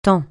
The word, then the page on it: tant: francais